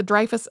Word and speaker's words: Dreyfus: Most English speakers in the United States say his name Dreyfus and call this the Dreyfus Affair, but in French, it is a lot more like Dreyfus.